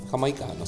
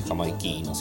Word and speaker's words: jamaicano: Jamaicano se dice, a mí me gusta decir jamaiquino, siempre dije jamaiquino, pero bueno, parece que se dice jamaicano.